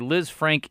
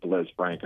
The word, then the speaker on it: Lisfranc: But he's Taysom's dealing with a Liz Frank injury, and it's spelled L-I-S-F-R-A-N-C, not like a woman named Liz Frank.